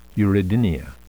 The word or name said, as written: uredinia